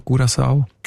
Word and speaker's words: Curaçao: Was sagt denn die Datenbank zu den Menschen von Curaçao? – Curaçao heisst die Insel, habe ich gelernt.